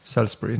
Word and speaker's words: Salisbury: ma arvan, võib-olla küll lindistatud, igatahes see hääl hääldas seda i-d võrdlemisi vähe, Salisbury nagu ütles rohkem, siis me mul, kui ma kirjutan Google'isse Salisbury's tuleb Salisbury Cathedral, Salisbury Attack ja Salisbury Church.